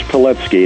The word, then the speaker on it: Pilecki: It looks like Pilecki, but it's pronounced Pilecki, and he's one of the chapters in Real Heroes.